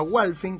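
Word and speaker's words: Hualfín: Cualquier persona en auto puede llegar a un pueblo que se llama Walfin, que se escribe H-U-A-L-I-E-F-I-N, ahí está, Walfin, y recorrer de ahí hasta Antofagasta de la Sierra, todo esto es Catamarca.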